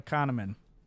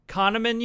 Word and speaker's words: Kahneman: Daniel Kahneman. – Kahneman, you say? – Yes.